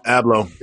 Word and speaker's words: Abloh: Now, Louis Vuitton, artistic director Virgil Abloh, excuse me if I'm pronouncing his name wrong. – Abloh.